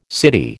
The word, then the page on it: city: American English